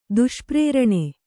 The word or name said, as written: ದುಷ್ಪ್ರೇರಣೆ